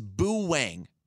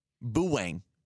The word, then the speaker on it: Boo Wang: And she said, the name is Boo Wang. Boo Wang. Okay. – How does one spell that? – I spelt it B-O-O-W-A-N-G.